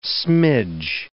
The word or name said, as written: smidge